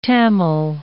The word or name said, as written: Tamil